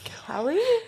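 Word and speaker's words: Keleigh: He really should try BYLT Basics by Keely Teller. – Kelly. Her name is pronounced Kelly.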